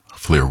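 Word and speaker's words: Llŷr: Flair Williams, the pianist. His name is spelled L-L-Y-R. Flair Williams.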